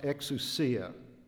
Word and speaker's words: exousia: The word for authority first is a Greek word pronounced exousia.